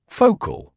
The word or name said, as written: focal